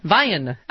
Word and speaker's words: Vian: Vian. In Los Angeles. Hello. – Hi, Stephanie. It's Vian. That's fine. That's close enough. Just like Brian or Ryan.